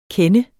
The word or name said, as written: kende